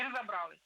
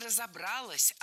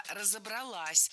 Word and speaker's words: разобралась: Я понимаю, что я выгляжу, ну, глупо, да, то есть я над собой посмеялась, говорю, ну, дурочка, слушайте, не разобралась, да? – Нет, не разобралась, а разобралась. Опять же, ударение ставим на последний слог, поближе к части «се». Разобралась.